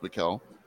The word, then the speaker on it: Michael: So that's like Michael E. Postel, but it's actually pronounced Mikkel.